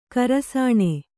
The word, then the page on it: ಖರಸಾಣೆ: kharasāṇe